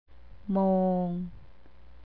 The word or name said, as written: mong